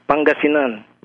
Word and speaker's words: Pangasinan: Oh, yes, Pangasinan.